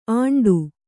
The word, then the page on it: ಆಂಡು: āṇḍu